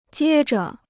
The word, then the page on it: 接着: jiē zhe